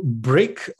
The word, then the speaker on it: Bryck: So I'm referring to Bryck, B-R-Y-C-K. That's how you spell it. I hope it's correct. So Bryck, what is Bryck? It's like a brick.